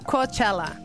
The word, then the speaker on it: Coachella: Rebeca y Marta hablaron sobre Coachella.